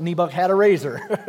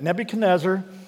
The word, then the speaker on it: Nebuchadnezzar: I heard one guy pronounce it, "Nebuch had a razor." It's a big name, isn't it? Nebuchadnezzar, maybe a new name to you.